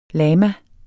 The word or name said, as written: lama